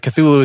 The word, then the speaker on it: Cthulhu: something that is cannot really be pronounced, but like Cthulhu is as close as you get.